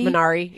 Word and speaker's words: Minari: Minari? – Minari, yeah.